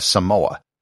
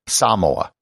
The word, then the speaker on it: Samoa: I'm going to be using the Samoan pronunciation, which is putting the accent on the first syllable and using an elongated A. Most people will pronounce it without any emphasis, just as Samoa, but it should be Samoa. Kind of similar to how Tuvalu is Tuvalu and not Tuvalu.